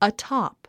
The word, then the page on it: atop: ətɑ’p; アトォップ